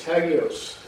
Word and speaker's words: Hagios: And then in the Greek, it's Hagios.